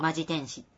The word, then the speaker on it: 本気天使: これ、カッコでね、読み方書いてくれてるの。 – ああ。 – マジ天使って読むらしい。